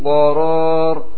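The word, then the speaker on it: qoror (قرار): Kalau tidak naik untuk membentuk huruf qaf, maka bacanya lurus. Min qarar. Min qarar. Tapi ketika kita siapkan huruf qaf, dengan otomatis pangkal lidah itu naik. Min, ya, min qoror. Dinaikin di sini karena mempersiapkan huruf yang setelah nun sukun ataupun tanwin.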